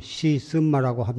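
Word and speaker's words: 시쓴마라고 (是甚麼): 이 무엇고 이 무엇고라고 하더는 한문으로는 시삼마고 중국 발음으로는 시쓴마라고 합니다마는 우리말로는 이것이 무엇인고 어, 갖추어서 발음을 하자면 이것이 무엇인고 이렇게 되겠습니다마는 줄여서 간결하게 말하자면 이 무엇고입니다.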